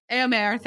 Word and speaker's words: Éomer: Eowyn and Éomer, how do we say his name? Éomer, thank you.